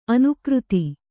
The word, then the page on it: ಅನುಕೃತಿ: anukřti